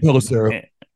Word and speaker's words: Pelissero: Report from NFL insiders Tom Pelissero, or whatever how you pronounce his name. – Pelissero.